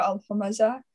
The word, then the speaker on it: al-Humazah: So Surah 104 is titled Surah al-Humazah. I hope I'm pronouncing that right. And Humazah. Yeah, emphasis on the first syllable.